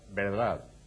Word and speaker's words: verdad: They say, for example, the little V, which comes from the Greek in Latin, in the Spanish word verdad, which means the truth, V-E-R, they pronounce it with a B. Verdad? Es verdad? Not verdad, as people would read it in English, but verdad.